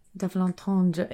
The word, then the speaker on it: double entendre: So Bond girls occasionally have names that are double entendres or like puns. Double entendre. Is that how you say it? – Double entendre.